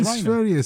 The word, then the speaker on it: Sverige: No, Sverige. Sverige is the right, correct pronunciation. Well done, you. Yeah, it's Sverige.